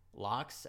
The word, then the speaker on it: Lox: Like now I'm listening to Lox, LOX.